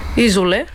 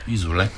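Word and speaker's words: Isole: Няма да имаме време за Изол или Золе. – Изоле. – Изоле, да, ударението ми се ми обегна, но пък те ще бъдат първата група на втората вечер от вашия Metal Weekend.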